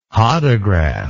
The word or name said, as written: hodograph